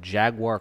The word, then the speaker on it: jaguar: And it is an imperial stout with mixe pasilla chili, honey, mandarin peel, and jaguar cocoa. – Can you pronounce it the right way, please? – Jaguar? – Oh, sorry. – It's a soft J.